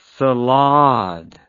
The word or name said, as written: salade